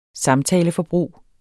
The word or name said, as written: samtaleforbrug